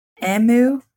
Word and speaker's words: emu: Emu, not emu. – I don't know. – I can't tell how she's phonetically pronouncing it. I just wanted you guys to know that E-M-U is pronounced E-M-U, not E-M-O-O.